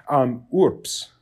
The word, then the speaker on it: urbs: Urbs, U-R-B-S. Well, the B sounds like a P because it's before an S.